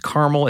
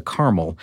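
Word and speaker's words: Carmel: This is a model called the Caramel, and it's C-A-R-M-E-L. It's caramel. It's not caramel like the candy.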